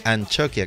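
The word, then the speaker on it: Antzokia: Y bueno, más cosillas que deciros, pues Yellow Man, ya sabéis, el 26 de noviembre estará en el Café Anchoquia. Y ahora lo digo bien, ¿por qué? Pues después descubriréis por qué digo bien. Anchoquia, que significa café teatro.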